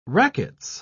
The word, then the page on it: racquets: British English